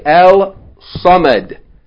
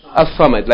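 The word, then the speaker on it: As-Samad (الصمد): You're not going to say Al-Samad. But you're going to say As-Samad, like this.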